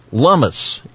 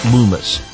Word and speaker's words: Lummis: Stop by and grab a snack and remember, Lummis, it rhymes with hummus. That Wyoming's new senator, Republican Cynthia Lummis, announcing that her office is giving away cups of hummus to promote the correct pronunciation of her last name. Her name is sometimes mispronounced as Loomis.